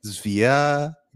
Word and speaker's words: Zvyagintsev: Zvyagintsev.